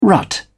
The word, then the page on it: rut: British English